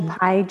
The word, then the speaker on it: Pieh: You can find me at Do Good Better on Substack, or even just search my name, Rachel Pieh Jones. So even my name actually is about food. It's pie, so you pronounce it the way that you would apple pie or 3.14. – Yeah, but with an H at the end, so Rachel, P-I-E-H, Jones.